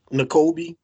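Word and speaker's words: Nakobe: N'Kobe.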